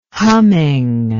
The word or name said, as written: humming